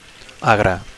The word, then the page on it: agre: Catalan